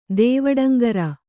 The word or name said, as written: ದೇವಡಂಗರ